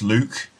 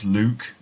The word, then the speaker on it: Luke: Now, the name Luke can be a little bit difficult for learners of English to pronounce sometimes. And I meet lots of people who can't pronounce my name, and they call me Luck or maybe Luck. But it's not Luck or Luck. It's Luke, of course.